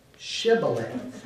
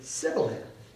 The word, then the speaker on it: Shibboleth: And because of linguistic differences in the land, they can't say Shibboleth. They pronounce the word Sibboleth.